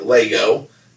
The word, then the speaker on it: Lego: Probably a lot of people collect Lego, or I always say Legos, but like Nevada, it's pronounced Lego.